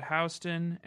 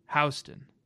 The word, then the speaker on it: Houston: She started at Houston and, sorry, Baby Nation, here in New York, we call it Houston.